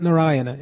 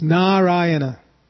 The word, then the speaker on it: Narayana: It's not Narayana. It's Narayana. Two long A's.